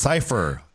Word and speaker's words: Cifer: Also, and it's Cypher. And you know, like Cypher, like, you know. – No, but this is spelled C-I-F-E-R. – Yeah, yeah. So yeah, like a cipher encryption.